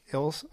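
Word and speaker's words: Elwes: First, read The Princess Bride book, yes, but no, the one by Carrie Ells. – Hills. Oh my gosh. Mispronouncing it.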